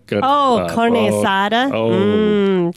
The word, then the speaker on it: carne asada: Carne asada.